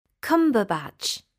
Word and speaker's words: Cumberbatch: Cumberbatch. – Sorry, can you spell that, please? – C-U-M-B-E-R-B-A-T-C-H.